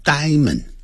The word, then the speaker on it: diamond: 这个你来查字典的时候，有些字典啊，就奇奇怪怪的就把这个音标念成了 diamond，di 还来个 a，diamond 没有这个发音的，老外是直接念成 diamond 啊，这样念 diamond。